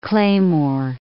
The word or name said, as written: claymore